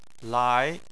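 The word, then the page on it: lai: tone 2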